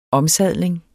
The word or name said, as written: omsadling